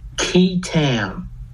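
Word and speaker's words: qui tam: There's something called a QI-TAM lawsuit. Have you ever heard of this? – I have not. – QI-TAM? Most people have not. It's spelled QUITAM.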